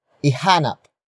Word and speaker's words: ihanap: Ihanap mo ng plorera ang bulaklak. Ihanap mo ng plorera ang bulaklak. The first word is ihanap. Ihanap. Ihanap. Ihanap is spelled as I H A N A P.